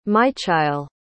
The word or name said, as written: maichail